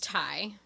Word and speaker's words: Tai: But also on the flip side of that, she takes the new girl played by Brittany Murphy under her wing, and her name is Tay. And so Ty? – I think it's Ty. – Ty, but it's spelled T-A-I.